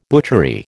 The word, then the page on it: butchery: American English